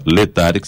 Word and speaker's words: Laetare: Letare, que se escreve L-A-E-T-A-R-E.